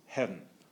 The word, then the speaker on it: heaven: So Dryden and Milton and probably Pope pronounced heaven mentally as a single syllable. It wasn't heaven, it was heaven, sort of like that. The V was there, but it was almost swallowed up between the E and the N.